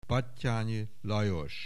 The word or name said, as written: Batthyany Lajos